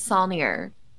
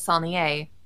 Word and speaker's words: Saulnier: The Texas pronunciation is Solnier. The proper pronunciation is Solnier. As I've been, you know, for long enough, and I think I'm the only one that says it that way in my family because I got yelled at uh, by a music instructor during call in college who told me I was saying my last name wrong.